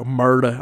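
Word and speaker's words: Murdaugh: Murda on June 7th, 2021. You got to say it like that. When I was watching the documentary, that's how they were, Murda.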